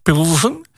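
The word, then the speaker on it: Plzeň: Je hebt bijvoorbeeld in Tsjechië een plaats Pilsen. Dat spel je P-L-Z-E-N.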